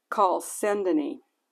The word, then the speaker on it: chalcedony: The first foundation was jasper, the second sapphire, the third chalcedonite. I think I pronounced that wrong. Chalcedony.